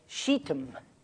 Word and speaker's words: Shittim: And then the valley of, I'll say this in Hebrew because it didn't sound good in English. I'll say the valley of Shittim, but it's spelled S-H-I-T-T-I-M.